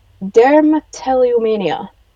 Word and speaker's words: dermatillomania: That's a big thing, um, but uh, it's it's already kind of got a diagnosis of, diagnosis of itself, and I had to look it up because I don't know how to pronounce it. So it's dermatillomania.